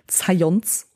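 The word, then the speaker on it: Zajonc: Aber das wiederum basiert auf dem Mere-Exposure-Effekt von dem Psychologen Science, das schreibt sich Zayong aber er selber hat Wert darauf gelegt, zu sagen, sein Name Science oder wie auch immer, Science rhymes with Science.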